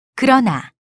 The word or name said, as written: 그러나